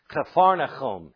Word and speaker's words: Capernaum: The Hebrew pronunciation for that village where Peter came from is Capernaum, and it means village of comfort.